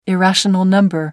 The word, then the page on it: irrational number: British English